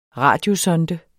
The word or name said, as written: radiosonde